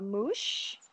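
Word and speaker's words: muxe: And she's what you call a mouche, which is spelled M-U-X-E-S, and it means the third gender.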